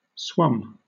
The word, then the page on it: swum: British English